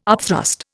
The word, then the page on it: upthrust: Indian